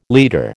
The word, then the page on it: leader: American English